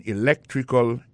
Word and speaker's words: electrical: I majored in electrical engineering. Electrical engineering. Ça veut dire accent tombé dans électrical là, c'est sur deuxième syllabe là. Electrical engineering. C'est sur deuxième syllabe là. Elect là, plus appuyé.